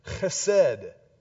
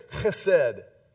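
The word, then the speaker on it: chesed (חסד): In the Old Testament, there's a great word that is pronounced chesed. It's a Hebrew word. Say chesed.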